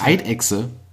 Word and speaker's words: Eidechse: Ähm, und ähnlich, das war auch ein ziemlicher Mindfuck, Eidechse. – Tja. – Spricht man doch als normaler Mensch aus wie Ei und Dechse. Aber es ist ja eine Echse. Also heißt es ja höchstwahrscheinlich Eidechse.